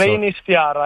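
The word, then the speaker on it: Reynisfjara: Eh sì, Reinisfjara, giusto? Poi la pronuncia non so. – Reinisfjara, esattamente.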